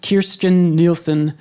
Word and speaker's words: Kirstjen Nielsen: Here in the States, in the last week, I believe, the Secretary of Homeland Security, I believe the name is pronounced Kirstjen Nielsen, came out and said that we were going to be, within the next 12 months, essentially ending the temporary protective status for...